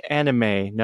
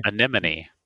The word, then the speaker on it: anemone: And how do you say this word? Anemone? Anime. No. It's A-N-E-M-O-N-E. – Anemone. – Anemone.